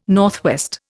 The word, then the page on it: NW: Indian